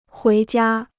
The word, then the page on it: 回家: huí jiā